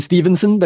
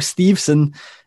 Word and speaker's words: Steveson: I keep pronouncing his name wrong. I keep going Stevenson because obviously that's just the way you would. So Gable Steveson apparently has held some talks with people for WWE.